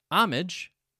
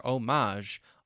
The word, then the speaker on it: homage: According to the Oxford Dictionary that I have on my computer, homage, with the main syllable stress or emphasis on the first syllable, not the second. My dictionary tells me that this is the accepted pronunciation for both British and American English. However, I cannot deny the fact any longer that I most definitely hear homage all the time.